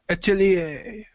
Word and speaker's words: Atelier: And it is Atelier. – Atelier. Hey, I was saying it right. – Atelier. No, you got to sound more French when you say Atelier.